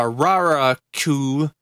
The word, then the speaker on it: Raraku: Most of the statues are located along the Rano Rakur, uh, Raraku. Sorry, I, I'm I'm butchering this this language to pieces here.